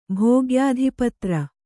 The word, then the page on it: ಭೋಗ್ಯಾಧಿಪತ್ರ: Kannada